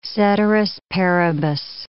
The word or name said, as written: ceteris paribus